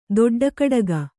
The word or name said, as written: ದೊಡ್ಡಕಡಗ